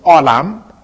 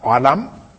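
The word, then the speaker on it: olam (עולם): The Hebrew word I wrote really is pronounced olam, olam. If those who want to write the Hebrew out can write O-L-A-M, olam.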